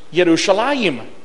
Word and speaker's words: Yerushalayim: Well, in Hebrew, it's Yerushalayim.